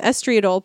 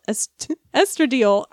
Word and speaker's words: estradiol: Consider, for instance, that the most common form of orally administered prescription estrogen today is probably the beveled, flat-faced 2-milligram estriol pill." Estradiol.